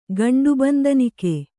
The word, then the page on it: ಗಂಡುಬಂದನಿಕೆ: kannada